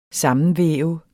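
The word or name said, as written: SAMMENVÆVET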